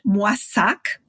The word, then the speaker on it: Moissac: So the town is pronounced Moissac. It's a French town in southern France.